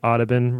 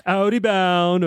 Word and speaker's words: Audubon: They always be on like pronouncing Audubon wrong. it's not Audi Bound or Udi Boon.